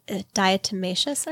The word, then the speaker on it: diatomaceous: Have you heard of Diametrius earth? – Yes. Diatomaceous earth? – Yes. – Yes. – I know exactly how you say it, but something like that.